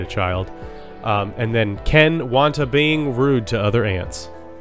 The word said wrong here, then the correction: Watanabe: Wanta not being rude. Sorry, I messed that up. He just Who is that? I miss I missed this syllable in his is. name. – Ken Watanabe is um the guy who plays uh, Katsumoto in the in the Last Samurai.